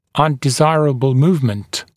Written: undesirable movement

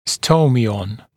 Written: stomion

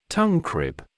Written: tongue crib

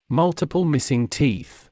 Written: multiple missing teeth

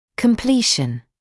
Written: completion

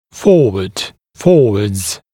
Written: forward, forwards

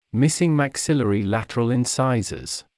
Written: missing maxillary lateral incisors

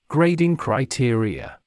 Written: grading criteria

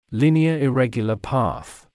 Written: linear/irregular path

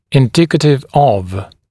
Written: indicative of

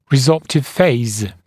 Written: resorptive phase

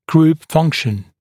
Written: group function

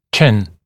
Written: chin

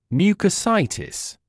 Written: mucositis

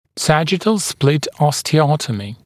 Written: sagittal split osteotomy

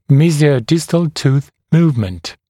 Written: mesiodistal tooth movement

